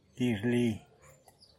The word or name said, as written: dizhli